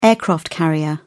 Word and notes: aircraft-carrier: British English

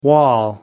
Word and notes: wall: American English

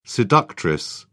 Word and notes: seductress: British English

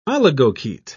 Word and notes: oligochaete: British English

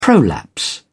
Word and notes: prolapse: British English

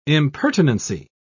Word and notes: impertinency: British English

